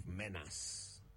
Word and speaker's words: menace: When you consider that the word can be pronounced as two separate words, men and ace, not men and ass, it becomes hard to explain the common mispronunciation of menace.